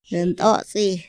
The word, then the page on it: ra nt´atsi: Otomí